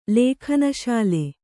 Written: ಲೇಖನಶಾಲೆ

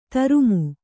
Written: ತರುಮು

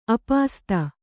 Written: ಆಪಸಾತು

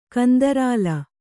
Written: ಕಂದರಾಲ

